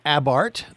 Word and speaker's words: Abarth: Let me tell you, I own a 2015 Fiat 500 Abarth. They say not, even though it is spelled A-B-A-R-T-H, they say Carl Abarth. It was how he said his name.